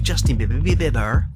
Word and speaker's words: Justin Bieber: Here's Billie Eilish, along with Justin Bieber. That's how you say his name.